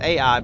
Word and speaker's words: Ai: And by the way, why did the next battle against the city of I've heard it pronounced Ai, but I think it's supposed to be I.